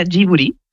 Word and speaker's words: Ghibli: En japonés se pronuncia Ghibli. Uh-huh. Porque ellos no tienen el sonido de la L, pero es una historia bastante graciosa porque el nombre lo elige Hayao Miyazaki y lo elige por la palabra japonés.